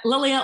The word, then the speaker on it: Lilia: Lilia, let it go, Sinclair.